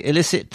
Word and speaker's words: illicit: Because the emphasis isn't on the first syllable. – Exactly. – And I think the first syllable is pretty much a schwa, isn't it? It's that schwa sound. – It's a schwa sound, which means that it's barely just a "uh". So illicit.